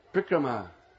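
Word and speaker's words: parikrama: It's a long A at the end. You have to pronounce it. You can't cut it off. Prikrama.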